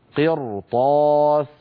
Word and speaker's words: قرطاس: كذلك الأمر في المثال الثاني، ولو نزلنا عليهم كتابا في قرطاس قرطاس، هنا الراء نطقتها مفخمة. لما؟ لأنها ساكنة كما نرى وقبلها مكسور وهو القاف، وبعدها حرف استعلاء غير مكسور وهو الطاء.